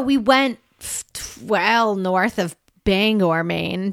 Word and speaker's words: Bangor: It wasn't far from Bangor, which I believe is how the locals would pronounce it.